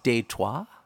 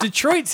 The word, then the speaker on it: Detroit: So wouldn't it be pronounced Détroit? – Oh. – Have we been saying it wrong this entire time? – Can you imagine? – Détroit, Michigan. – He's like, where are you from? – Détroit. – It doesn't have the same... Like, Detroit sounds like you might get shanked up in an alley...